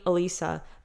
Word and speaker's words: Elissa: perché per me non chiedo, devi chiamarmi Elisa, perché è difficile da pronunciare quando stai parlando una lingua.